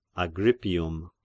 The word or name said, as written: Agrippium